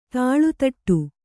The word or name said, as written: ತಾಳುತಟ್ಟು